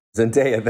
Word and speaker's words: Zendaya: would definitely be Zendaya. Am I pronouncing her name correctly? When she won, that was Zendaya. Such a magic yeah. moment. Zendaya, thank you.